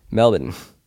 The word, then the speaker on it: Melbourne: We just booked an Airbnb in Melbourne. – There we go. Oh, so way to pronounce it correctly. – Huh? – Way to pronounce that correctly. – What do you mean? – Melbourne.